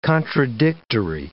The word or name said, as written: contradictory